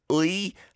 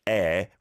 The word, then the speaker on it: 의: Ui, how it's spelt, but said air, remember, denotes possession.